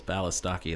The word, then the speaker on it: Balistocki: my last name has four syllables, Balistocki.